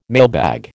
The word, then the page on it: mailbag: American English